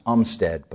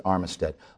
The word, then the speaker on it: Armistead: General Kemper placed his five regiments to Garnett's immediate right, while General Armistead, and it may be argued that his name was pronounced Umstead, but I'm going to pronounce it Armistead, aligned his men to Garnett's left rear.